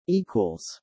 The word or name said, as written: equals